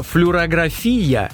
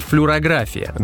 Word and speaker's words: флюорография: Она спрашивает, почему в слове «флюорография» делают ударение на последний слог? Разве не логично, что это как фотография, то есть должна быть флюорография? – Да, конечно, правильное ударение «флюорография», но правильное в том смысле, что оно соответствует литературной норме. Но у медиков употребляется вариант «флюорография», и словари это специально отмечают.